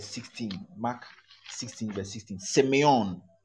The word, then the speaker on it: semeion: The Greek for sign is semeion. Let me spell it for you. semeion. S E M E I O N.